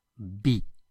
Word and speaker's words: vi: And finally we'll use a past tense and we'll say vi, V-I-B, which means I saw. Obviously you'll notice that the letter V is pronounced like a B in Spanish.